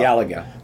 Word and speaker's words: Galaga: I used to call it Galaga, because Galaxian Galaga, and everybody else calls it Galaga. – Galaga. – But we found out that the official name, the official way it's pronounced... – According to Midway, is Galaga.